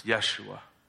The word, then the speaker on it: Yeshua: And we pronounce it a little different, it's Yeshua. But it's the same thing as Joshua.